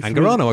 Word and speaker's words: Angarano: Angarano, I got it right.